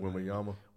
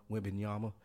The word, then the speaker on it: Wembanyama: They get Obenwamba or whatever his name is. I can't never pronounce it. – Wembanyama. – Wembanyama.